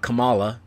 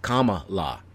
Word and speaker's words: Kamala: It's not Kamala. It's Kamala. Like, comma. And then L.A., Kamala Harris.